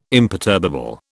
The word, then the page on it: imperturbable: British English